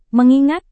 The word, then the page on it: mengingat: Indonesian